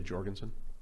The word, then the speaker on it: Jurgensen: Jorgensen? Is a hard or soft J? – I would say Jorgensen.